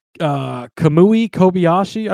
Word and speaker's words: Kamui Kobayashi: Kamui Kobayashi, I believe is how you say his name.